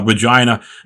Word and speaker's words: Regina: Uh, Regina, and yes, I'm saying that right, uh, has a great stadium.